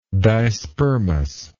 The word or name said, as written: dispermous